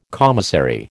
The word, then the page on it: commissary: American English